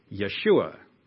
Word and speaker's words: Yeshua: Jesus is the English pronunciation of the Greek name Jesus, which is actually the Greek transliteration from Hebrew, the name Yeshua, which in English we, of course, pronounce Joshua. Now, aren't you glad you know that?